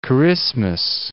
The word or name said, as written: Kiritimati